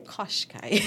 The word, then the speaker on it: Qashqai: The 7, a mid-size SUV competing with models like Nissan, Quashi. Did I say that right? – Qashqai. – Yes.